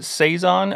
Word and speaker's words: saison: I pronounce it Saison. And from the people I've talked to, they've always pronounced it Saison.